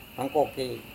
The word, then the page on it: mangkokei: Serewen